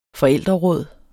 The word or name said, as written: forældreråd